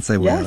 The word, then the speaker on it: cebollada: Carne frita, and let me see if I can pronounce this correctly, and cebollada. And cebollada? – Yes. – And cebollada. – Yes, you said it right. That's perfect.